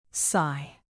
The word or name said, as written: sigh